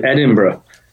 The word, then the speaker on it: Edinburgh: We actually pronounce it Edinburgh, but I know that many people in the States have a real problem with that pronunciation, so we forgive you, that's fine.